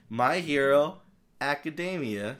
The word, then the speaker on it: My Hero Academia: My Hero Academia, Season 2, Part 1.